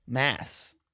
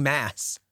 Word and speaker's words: Mads: No, Mads Mikkelsen. Mads. – Why do you keep saying mass? – This is how it's pronounced. – His name is pronounced mass? – Mm-hmm. – Is it not like M-A-D-S? – That's how it's spelled.